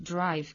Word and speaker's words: drive: En este caso, la respuesta correcta sería la A, es decir, drive, que se parece a el sonido vocálico, es el mismo que el de five, es un diptongo.